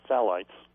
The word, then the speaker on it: phthalate: One of the chemicals called phthalates, it's spelled P-H-T-H-A-L-T-E-S, and pronounced phthalate, these chemicals can actually damage DNA in both the male sperm and in the mother's egg.